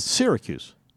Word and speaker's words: Syracuse: It's between Sarah or Syra. Syracuse or Syracuse? – It's Syracuse.